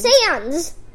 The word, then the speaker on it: Sans: Look, it's Sans.